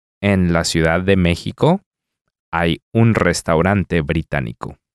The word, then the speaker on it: hay: It sounds like one of these silent H scenarios to me, Hector, so I'm going to guess H-A-Y.